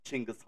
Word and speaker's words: Chinggis: I have friends who live in Mongolia, so I'm going to use a closer to Mongolian pronunciation of his name, of Chinggis Khan.